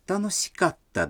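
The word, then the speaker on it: Таносикатта (楽しかった): Таким образом, это «весело» – «таносий дес» превратится в «таносикатта дес». Было весело. – Слушайте и повторяйте. Катта произносится с короткой паузой между ка и та.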